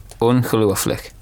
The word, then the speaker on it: Ongelooflik: Ongelooflik. So it's like a lu as opposed to u. It's u. Yeah. – Ongelooflik.